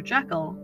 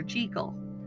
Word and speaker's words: Jekyll: You may have noticed that I have been pronouncing what you expected to be Dr. Jekyll as Dr. Jekyll. This is actually the correct pronunciation as Robert Louis Stevenson wrote it.